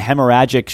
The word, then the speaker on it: hemorrhagic: he suffered from a hemorrhagic stroke.